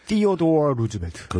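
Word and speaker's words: Theodore: 19세기 말에서 20세기 초까지 미국 대통령을 했던 티오도어 루즈벨트. 그렇죠. 예, 발음이 아주 정확하시네요.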